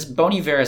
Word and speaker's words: Bon Iver: Is Bon Iver is how you say that, right? – Yeah.